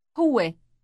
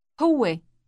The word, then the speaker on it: هو: هو هو This word contains a long w sound in the middle. It sounds as if it is pronounced twice in quick succession.